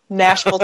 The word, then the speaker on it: Nashville: You know, the locals like to rhyme it with bashful, so you can always go that way, Nashville, Tennessee.